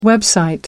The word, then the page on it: website: British English